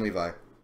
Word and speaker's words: Levi: Yeah, Captain Levi.